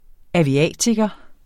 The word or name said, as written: aviatiker